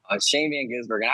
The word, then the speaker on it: Shane Van Gisbergen: Shane Van Gisbergen.